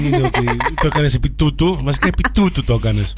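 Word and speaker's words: tutu: Τούτου, βρε, βρε, τι τούτου, τούτου. – Τι τούτου, τούτου είναι τα μάξι τα μορουδίστικα. Βάλε λίγο γαλλική προφορά. Τούτου.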